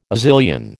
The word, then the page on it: Azilian: American English